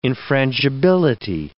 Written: infrangibility

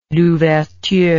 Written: Louverture

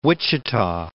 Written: Wichita

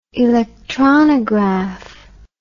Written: electronograph

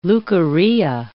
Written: leukorrhea

